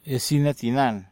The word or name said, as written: iasinatinan